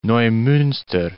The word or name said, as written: Neumunster